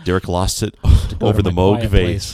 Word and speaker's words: Moog: Moog. – Moog bass. – Moog. Rhymes with Vogue. Vogue.